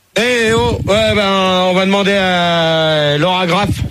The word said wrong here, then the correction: Lara Croft: Lara Croft.